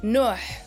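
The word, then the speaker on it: Nuux: It's actually the X is silent, so it's Nuh, but you could just say Amaal.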